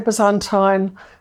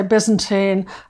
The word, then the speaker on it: Byzantine: Well, perhaps starting with the first thing, how do you pronounce Byzantine? Because I keep on hearing people say Byzantine, and I'm thinking, oh no, have I been saying it wrong all my life? – No, I say Byzantine. Americans say Byzantine. I've been in the States a lot, so I sometimes say Byzantine as well, which is very confusing.